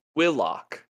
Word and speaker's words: Willock: Also, his name's not pronounced Willock. – Is it? – It's Willock. – Willock. – With like a aw in Willock. The O, which is very awkward to say.